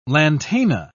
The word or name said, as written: lantana